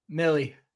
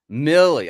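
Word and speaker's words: Milley: What is it, Miley or Millie? – It's Murray. – Is it Millie or Miley? – Millie. – Millie.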